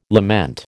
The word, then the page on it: lament: American English